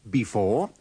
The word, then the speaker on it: before: Before and after. Now listen to these sentences. Remember to pronounce the first E in before with the schwa.